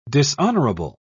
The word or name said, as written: dishonorable